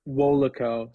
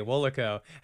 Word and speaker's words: WOLACO: You pronounce it how do you say it? – WOLACO. So you WOLACO. Put like a hard out. I mean, I'm like going hard with the A, WOLACO.